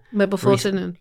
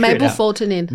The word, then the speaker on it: mebufotenin: Mebufotenin. – Okay, how do you pronounce that? I've been trying to figure Mebufotenin. – It out. Mebufotenin.